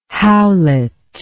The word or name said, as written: howlet